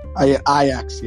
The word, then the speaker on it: Ajax: In round 10, we've got Duke men's basketball, Kentucky men's basketball, Notre Dame Fighting Irish football, and Ajax FC. I said it right, right? – Ajax, yeah.